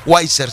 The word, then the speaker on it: Weiser: Él es el pastor de nuestra congregación y estamos ubicados aquí en la misma ciudad, en el 3 de la Weiser Avenida. Weiser se escribe W, E de Eddie, S de Samuel, E de Eddie, R, Weiser,